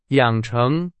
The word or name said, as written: 养成